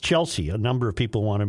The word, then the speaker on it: Chelsea: He continually says Chelsea, and it is very aggravating. The name Chelsea is pronounced Chelsea, not Chelsea.